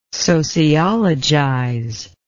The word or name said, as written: sociologize